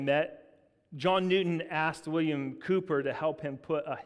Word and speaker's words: Cowper: It's spelled C-O-W-P-E-R, but it's Cooper, not Cowper.